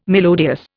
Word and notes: melodious: Indian

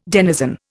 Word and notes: denizen: Indian

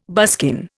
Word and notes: buskin: Indian

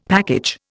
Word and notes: package: Indian

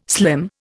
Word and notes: slim: Indian

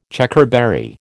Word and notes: Checkerberry: American English

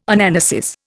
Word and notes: Anamnesis: Indian

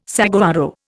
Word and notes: saguaro: Indian

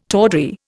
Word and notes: tawdry: Indian

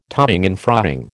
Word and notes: toing and froing: American English

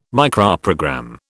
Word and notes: Microprogram: British English

